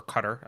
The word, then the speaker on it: Qatar: You know, that's one of the things that I – have you seen Cernovich's – has anyone seen Cernovich's short documentary on Qatar? Or Cutter, I guess it's pronounced.